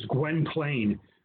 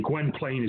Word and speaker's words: Gwynplaine: And in it, he plays a character known as Gwynplaine, or Gwen Plaine. You can pronounce it either way. Gwen Plaine is usually how it's pronounced.